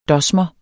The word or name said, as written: dosmer